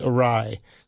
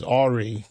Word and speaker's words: awry: And I like that you knew it was pronounced awry. I've heard people mispronounce it as awry because they, you know, never heard it pronounced.